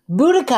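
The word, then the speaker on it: Boudicca: Boudica.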